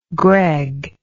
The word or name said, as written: Gregg